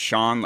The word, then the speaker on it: Jon: I've got this kind of curse, kind of gift that my name is actually pronounced Sean, like Sean, but it's really spelled J-O-N. So if anyone's calling for like is looking for money or something, they're gonna call and ask for John.